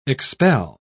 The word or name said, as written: expel